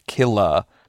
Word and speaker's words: Killah: I need to note that the title of Killa by Lady Gaga is spelled K-I-L-L-A-H, hence the pronunciation.